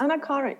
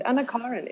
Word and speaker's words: Anna Karin: So it's a very thick Swedish male accent, and he says like Anna Karin. Is that close? – Perfect. Anna Karin. Yeah, Anna Karin. That's good. Anna Karin.